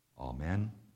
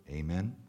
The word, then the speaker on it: amen: And then finally, question 129, what is the meaning of the word amen, or if you prefer, amen, you can pronounce it both ways.